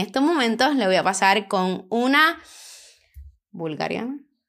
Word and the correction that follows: búlgara: ¿Se dice así? ¿Viste? ¿Viste? Búlgara. No es.